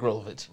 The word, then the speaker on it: Rolovich: And, Nick Rolovich, that's how you pronounce his name, right? – Yeah, Nick Rolovich.